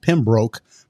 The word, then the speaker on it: Pembroke: The town looked like it would be pronounced Pembroke, but down there they pronounced it Pembroke. – Pembroke, yeah.